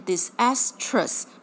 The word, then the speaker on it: disastrous: 好，所以长久下来，人们这样子念了之后，发现 disastrous 比较顺，所以那个 e 久而久之就不见了，所以在发音上不见，拼字上不见了。